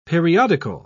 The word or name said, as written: periodical